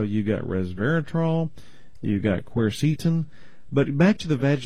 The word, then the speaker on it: quercetin: The other one, yeah, it's a flavonoid that you find in apples and onions called quercetin. That's the way I pronounce it. Some people call it quercetin. I call it quercetin.